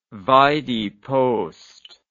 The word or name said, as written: vide post